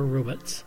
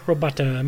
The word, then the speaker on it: robot: Many pronounce it robot, or thinking it is from French origin and pronounce it as robo. Neither is correct. The O is long in the first syllable, and the second O is not pronounced. Thus, robot. For my listeners down south, most of you might already know where the word Dixie got its origins.